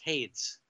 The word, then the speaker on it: Hades: I believe it's pronounced Hades.